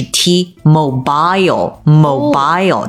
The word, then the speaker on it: T-Mobile: 如果是英式发音的话就是 T-Mobile,Mobile,、它重读第二个音节 Mobile。